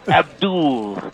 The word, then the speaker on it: Abdul: Abdul. Rhymes with didn't go to school.